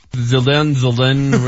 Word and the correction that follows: Zinedine Zidane: whatever. What is it? Zildane? – Yeah, Zildane is turning 46 today. That's Zinedine Zidane, for those of you keeping score at home.